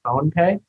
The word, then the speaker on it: PhonePe: And then one interesting asset Flipkart owns that I didn't know about is PhonePe. It's spelled P-H-O-N-E, so just phone, and then P-E.